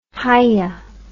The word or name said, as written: hiya